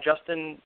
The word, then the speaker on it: Juston: Well, yeah, so I'm on Instagram, Justin R. Graber, but it's spelled J-U-S-T-O-N, not I-N.